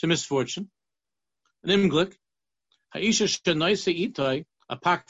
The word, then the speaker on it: Unglück: What's Unumglik? Did I say it wrong? – Yeah, misfortune, yeah. Unglück, yeah. – Unglück.